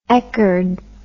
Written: echard

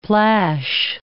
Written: plash

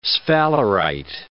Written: sphalerite

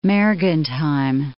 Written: Mergentheim

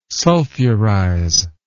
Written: sulfurize

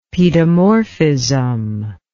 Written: pedomorphism

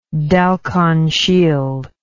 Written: Dalkon Shield

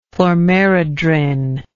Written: chlormerodrin

